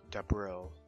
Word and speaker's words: Debril: Debril. – It makes me look, it's like Da and April, but with a B. – Debril.